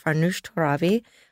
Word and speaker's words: Farnoosh Torabi: So anyway, we are so happy to have Farnoosh Torabi on. Am I pronouncing you right? – Perfect.